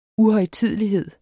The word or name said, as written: uhøjtidelighed